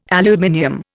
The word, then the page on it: aluminium: Indian